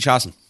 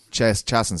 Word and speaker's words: Chiasson: Macy, how does he say your second name? Chiasson? – Macy Chiasson. – Chiasson, sorry. Yeah.